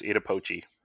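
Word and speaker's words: Iida Pochi: And today, we are looking at Volume 1 of Elder Sister Like One, written by, is it Ida Pochi? Is that how you say it? – I think it's Ida Pochi.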